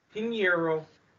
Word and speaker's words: Piñeiro: But a certain um, kicker, Eddy Piñeiro, or however the fuck you pronounce his last Piñeiro. goddamn name, Piñeiro.